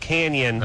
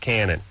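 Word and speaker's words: Cañon: It is pronounced Canyon, okay. – But they call it Cannon. – I see. Okay. Got it. – It has an N-N-Y-A.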